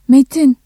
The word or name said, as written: metin